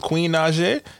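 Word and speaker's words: Naija: That Neo and Jeremiah remix with Lil Durk and Queen Najee, that's how you say her name, right? – Yeah.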